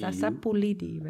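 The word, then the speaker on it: sapu lidi: Well, how do you pronounce it? – S-A-P-U. – Sapolidi, right? – Space. And L-I-D-I.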